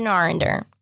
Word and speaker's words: Arender: Robin Arender. – And how do you spell that last name? – A-R-E-N-D-E-R.